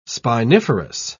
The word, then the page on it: spiniferous: British English